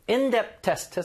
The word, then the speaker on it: in-depth: 자, 요거는 in-depth test 해서 약간 TH 발음이 거의 없는 것처럼 발음하셔도 돼요.